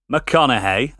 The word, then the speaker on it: McConaughey: This film, starring Matthew McConaughey, that's how you say it, Kate Beckinsale, Patricia Arquette, and in a role of a lifetime, Gary Oldman.